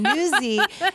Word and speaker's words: Nuzzi: and you pronounced her name Nuzzi, and I'm like, thank God,